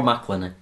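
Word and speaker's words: McElhenney: Rob Macklin. – Yeah, that's how you say it.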